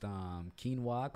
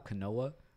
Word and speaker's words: quinoa: I remember one chick, she pronounced quinoa, canoa.